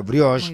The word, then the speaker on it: brioche: Obviamente, Mi Mago, el pan artesanal premium de masa madre y brioche, como le gusta decir a Alejandro, me enseñó a pronunciarlo, brioche.